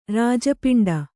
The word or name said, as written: ರಾಜಪಿಂಡೆ